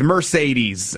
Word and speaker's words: Mercedes: I had, my mom was like calling me and like yelling at me, was like, you're pronouncing it wrong. It's not Mercedes. It's Mercedes.